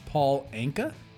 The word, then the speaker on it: Paul Anka: Paul Anka? Am I saying that right? – Yes, you are.